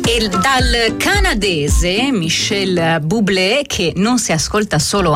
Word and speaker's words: Bublé: Con la E aperta, Bublé, che è canadese, non so se lo sapevate, come anche Céline Dion, lei invece è alla francese, la pronuncia.